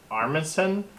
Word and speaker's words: Armisen: Fred Armisen? – Yeah. – I think that's how you say the name? – Yeah, that's how you say it.